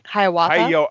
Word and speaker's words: Hiawatha: Did I say that right? – Hiawatha. Hiawatha. Yeah, I believe so.